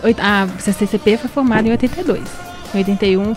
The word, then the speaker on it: CCCP: Fiquei com a sensação de falar de um C a mais, mas não, falei certo. CCCP, Fedele Alla Linea e a canção Io Sto Bene.